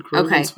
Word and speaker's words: Croghan: And I don't know how to pronounce it. It's spelled C-R-O-G-H-A-N. Krogan? I'm going to say Krogan. – That was not that hard of a one, though. – Krogan? – Just Krogan's fine. Krogan's fine.